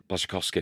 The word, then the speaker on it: Błaszczykowski: Błaszczykowski.